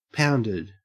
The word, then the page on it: pounded: Australian English